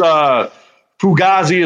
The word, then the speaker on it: Fugazi: Oh, it's Fugazi is how you say it.